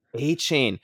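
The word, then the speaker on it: Achane: And then he also has this Akani. – A-chain. – Is that how you say it? Akani? – Devon A-chain. – Oh, A-chain.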